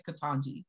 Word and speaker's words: Ketanji: I think it's Ketanji.